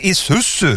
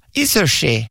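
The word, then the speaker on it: Isuzu: Alltså, du menar din isusu? – Det var ju det jag sa, isushi.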